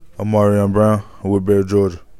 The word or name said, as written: O'Marion Brown